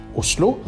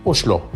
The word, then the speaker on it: Oslo: Nemlig hvordan skal navnet på Norges hovedstad uttales? Er det Oslo eller Oslo? Jeg har sagt at den tynne S-en kan ikke lenger gjøre krav på noen enerett, men den har fortsatt rett til sameksistens, har jeg sagt i språkbrevet.